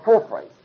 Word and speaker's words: Porphyry: I never spelled his name correctly, but it goes something like this, P-O-R-P-H-O-R-Y, something like that, and it's spelled Porphyry. P is pronounced like an F.